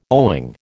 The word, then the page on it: owing: American English